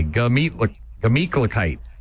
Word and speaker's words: Gemütlichkeit: Not a gla. Just G-A. Gemütlichkeit.